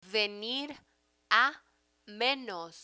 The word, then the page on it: venir a menos: Spanish